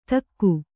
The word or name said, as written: ಧಿಕು